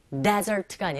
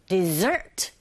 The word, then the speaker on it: dessert: 발음도 desert가 아니라 dessert 이렇게 발음한다는 거.